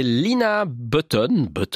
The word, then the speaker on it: Lina Button: C'est Lina Button, on va prononcer ça à l'anglaise, avec le titre AD Merci.